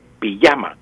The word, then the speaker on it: pijama: Póngase la pijama, salió en pijama aunque lo escribamos con J, pues pronunciamos con Y, pijama, el pijama o la pijama, la pijama.